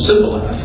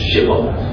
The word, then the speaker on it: Shibboleth: Can't you pronounce Shibboleth? You're saying Sibboleth. It's a Shibboleth. There's a difference.